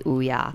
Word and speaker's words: Ouya: Warning, they asked, how do you pronounce Ouya anyway? And it is pronounced correctly, Ouya.